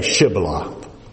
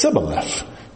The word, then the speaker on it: Shibboleth: And so Jephthah said, just ask them to say Shibboleth. And the Ephraimites could not pronounce a Shibboleth. All they could say was Sibboleth, they could not pronounce the SH.